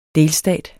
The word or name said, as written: delstat